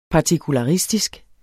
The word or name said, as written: partikularistisk